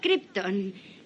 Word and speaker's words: Krypton: Procedo del planeta Krypton. – ¿Cómo? – ¿Krypton? – Ah, Krypton. C-R-I... – No, no, no, se escribe así... K-R-Y-P-T-O-N. – Ah, K-R-Y, le gusta el rosa.